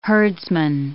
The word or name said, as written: herdsman